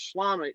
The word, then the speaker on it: Schlamme: Of course, creator Aaron Sorkin will also be there, and producer-director Thomas Schlamme.